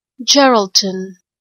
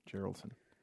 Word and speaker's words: Geraldton: when you put into Google, how do you pronounce Gerald? This actually comes up. So hang on a sec. Let's have a listen to what this says. Geraldton. Mm, okay, Geraldton.